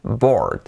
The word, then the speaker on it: bored: Pero aplicando la regla que os digo de mi estado, estoy aburrido, I'm bored, cuidado la pronunciación, que es bored.